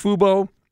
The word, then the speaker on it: Fubo: Well, I think the answer here now, Derek, is probably, is it pronounced Fubo? Fubo subscription. – I do believe so.